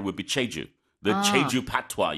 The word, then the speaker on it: patois: Now, what has emerged instead is an idiosyncratic patois.